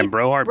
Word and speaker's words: Brohard: And then the next day we went and picked Mackenzie up and went to Brohard. Am I saying that right? – Yeah, Brohard. – B-R-O-H-A-R-D. Yeah, Brohard Paw Park.